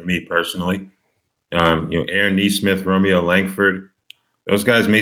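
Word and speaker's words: Nesmith: I guess Aaron Neesmith, is that how you pronounce his name? – Yeah.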